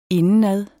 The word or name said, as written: indenad